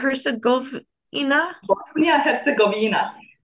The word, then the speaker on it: Bosnia-Herzegovina: Bosnia-Herzegovina.